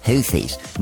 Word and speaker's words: Houthi: Houthis. – No, it's Houthi. They don't pronounce the H.